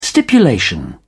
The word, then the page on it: stipulation: British English